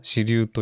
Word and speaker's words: 四立: そしてその 4 つの日のちょうど中間点が支流というそうですね 4 つ立つって書くんですけども支流と読むんですね。